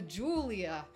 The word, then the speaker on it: Giulia: It's G-I-U-L-I-A. – Yeah, it's pronounced Julia.